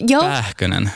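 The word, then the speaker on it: Pääkkönen: Uttalar jag namnet rätt? – Pähkönen. – Pähkönen. – Ja.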